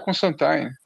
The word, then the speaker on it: Constantine: Pô, não é Constantine, é Constantine, né? É, que a pronúncia do nome é Constantine.